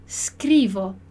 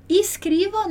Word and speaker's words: Scrivo: E aí, toda vez que a gente tiver um S mudo, a gente tem que prestar atenção para não pronunciar um I ou um E antes, porque ele não tem ali, não tá. Eu começo a falar a sílaba só com S, né? Scrivo. Dillo chiaro? Scrivo. Perfeito. Então, cuidado para não falar escrivo nem escrevo, né? Scrivo.